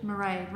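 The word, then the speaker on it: Mairéad: Mairead, rhymes with parade.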